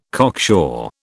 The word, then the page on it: cocksure: British English